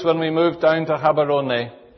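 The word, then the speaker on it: Gaborone: It was then just a small town called Habarone. You'll notice it's spelt with a G, but we say H. We never say G in Setswana. So it's Habarone.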